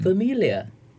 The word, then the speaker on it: familiar: Look familiar. – 对，the syllabic stress is on the second syllable, so it's familiar. – Familiar. – Yeah. Familiar. And because it ends with the letter R, I would always advise people to drop the R. Don't roll the R.